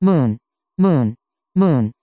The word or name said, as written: moon